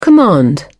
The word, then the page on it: command: British English